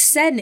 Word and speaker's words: Seine: Well, it's Seine in French.